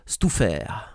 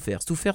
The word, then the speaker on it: Stouffer: Alors, je touffais, ça s'écrit, certes, je touffais, mais ça se prononce Stouffer. Stouffer, c'est le chef du village de Rêve d'Aventure.